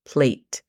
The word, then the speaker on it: plate: Eh, la pronunciación fonética es tan fácil como P-L-E-I-T, plate.